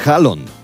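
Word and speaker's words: Haaland: a decir el nombre real de Erin Halland, que es Hallon. La doble A en los idiomas escandinavos se pronuncia como una O.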